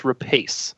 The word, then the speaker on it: Rapace: but it's Rapace, according to her.